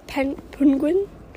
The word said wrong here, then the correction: Pugin: As the assistant to the talent, I will help with a little bit of pronunciation here. We're looking at. – Pugin? – Okay, yeah, that was probably that was as good as it gets.